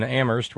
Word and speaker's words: Amherst: Thank you to Terry in Amherst, where only the H is silent.